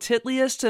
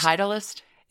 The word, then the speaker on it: Titleist: And he started raking in huge, huge amounts of money he inked sponsorship deals with Nike and the golf ball manufacturer whose name is spelled titliest, and I'm certain that that is pronounced Titleist.